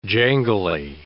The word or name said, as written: jangly